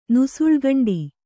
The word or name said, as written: ನುಸುಳ್ಗಂಡಿ